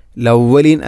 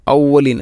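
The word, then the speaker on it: ഔവലീൻ (الأولين): അസാതീറുൽ അവലീൻ, ലവലീൻ അല്ല ഔവലീൻ, ഹംസയെ കൊണ്ടാണ് പറയേണ്ടത്. അസാതീറുൽ അവലീൻ.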